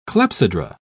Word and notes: clepsydra: British English

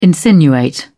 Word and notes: insinuate: British English

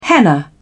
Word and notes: henna: British English

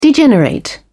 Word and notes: degenerate: British English

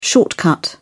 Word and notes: shortcut: British English

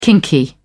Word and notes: kinky: British English

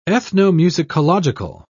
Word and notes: ethnomusicological: British English

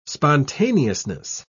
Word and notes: spontaneousness: British English

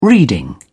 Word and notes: reading: British English

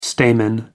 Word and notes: stamen: American English

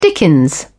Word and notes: dickens: British English

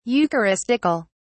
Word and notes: eucharistical: British English

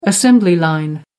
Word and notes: assembly line: American English

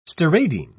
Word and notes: steradian: British English

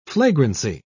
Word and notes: flagrancy: British English

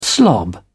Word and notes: slob: British English

